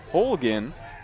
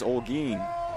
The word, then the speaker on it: Olguin: Of course, we had to get a pronunciation guide out there to make sure we didn't pronounce it the way it looks, which is spelt like Holgin. But in this case, it is Olguin, like Seguin.